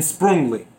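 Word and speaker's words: Sprüngli: I believe that's how you pronounce that second one. Lint and Sprungli. It's Sprungli.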